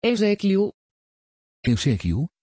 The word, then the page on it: ezekiel: Dutch